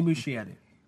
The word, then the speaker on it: Muschietti: Mama, also directed by Andy Muschietti. – Muschietti. – Whatever. – Still saying it. I can't pronounce words. It's my problem that I have. Andy Muschietti. – There you go. – Better.